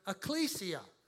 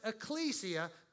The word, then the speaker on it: ecclesia: It's pronounced ecclesia because I checked with our local scholar, Nick Birmingham. I went to the Nictionary, the Nicothoris, and found out that this is the correct way to say it.